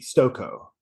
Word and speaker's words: Stokoe: S-T-O-K-O-E, Stokoe. – K-O-E. All right. Okay, excellent. Yeah, James Stokoe. I did say it right.